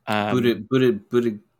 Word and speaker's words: Buttigieg: And anyway, at the event, Mike Pence said this, but what you need to know is that Pete Buttigieg, is that how you say his name? – Buttigieg. – I messed it up for you.